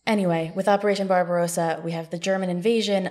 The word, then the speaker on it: Barbarossa: It's Barbarossa, right? – Barbarossa. – Barbarossa. What did I... yes, Barbarossa. – We have one more syllable in there.